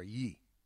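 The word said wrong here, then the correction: Ye: Yay, not Yee. You can't say Yee. Got to say yay. – Then he should spell it Y-A-Y.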